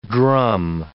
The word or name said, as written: drum